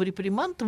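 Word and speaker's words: réprimande: Изначально значение слова «репримант» — выговор. Я посмотрела, и это от французского слова «реприман», наверное, последнее не произносится. Выговор. Не читается, да.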